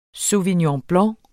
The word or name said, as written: SAUVIGNON